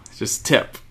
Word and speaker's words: Tippe: It's spelled T-I-P-P-E. – Yeah. – Tip. No, just tip.